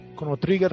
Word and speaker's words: Chrono Trigger: es un empate técnico entre Chrono Tiger, Chrono Trigger realmente, mal pronunciado Trigger, Chrono Trigger y Mario 64.